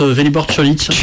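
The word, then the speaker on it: Vélibor Čolić: On prononce Vélibor Kölik. – Kölik. – Ah oui, Kölik, désolé. Oui.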